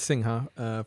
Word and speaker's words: Singha: It's Singha from Thailand, brewed in Bangkok.